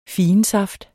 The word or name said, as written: figensaft